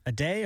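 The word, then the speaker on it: Aday: How would you pronounce this? A-D-A-Y. A day or? – A day? Yeah.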